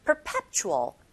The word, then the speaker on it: perpetual: 혀끝이 윗니 아랫니 사이에 살짝 긁혀지고 perpetual. Perpetual, 강세, 꽝, 에, 이 부분에 주시고 snow 뒤에 on its head에서 on its head, on its head 이런 식으로 연결해서 발음하시면 됩니다.